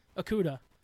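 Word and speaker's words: Okudah: Okuda.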